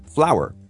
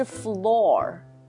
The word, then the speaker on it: flour: Flour. – Flour, 面粉、mm-hmm. F-L-O-U-R, 对不对？ Right. 对 ,Flour, 它不是 floor.